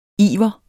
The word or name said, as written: iver